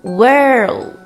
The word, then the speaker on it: world: World, word, world, word，这两个单词的读音应该是很多同学的老大难问题，很容易就读混了。长得也很像，只差一个字母 l，读音自然也就只差一个模糊舌侧音。 World, world 是世界，word, word 是单词，要注意区分哦。